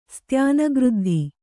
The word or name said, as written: ಸ್ತ್ಯಾನಗೃದ್ಧಿ